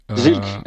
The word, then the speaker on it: Zilch: Ouais, euh, Zilch, Zilch.